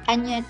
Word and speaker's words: Ajna: Sí, el, chakra sexto, eh, sánscrito se llama Aña Chakra. Se escribe A-J-N-A.